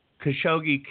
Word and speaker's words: Khashoggi: Khashoggi killing there in the.